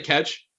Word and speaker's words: catch: I say catch like it rhymes with fetch. The the emailer and Adam don't think catch and fetch rhyme. They think it's catch.